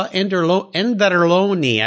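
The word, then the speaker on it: Anderloni: But, yeah, Carlo Enderloni, I believe is the right way to say his last name, the designer of that just simple, clean, wonderful little car.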